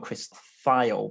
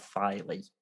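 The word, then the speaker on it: Thile: I think half the time Chris, you'd pronounce it Tiley. – Chris Thiley. I would have gone Chris File, but I heard somebody, I think it was Corey Wong, I heard introduce him and said Thiley.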